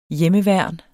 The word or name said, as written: hjemmeværn